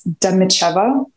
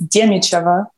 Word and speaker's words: Dimicheva: If you want to go very, very perfect, it's Dimicheva in English, and it's Dimicheva in the Russian and Ukrainian pronunciation.